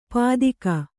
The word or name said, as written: ಪತ್ತಿಗ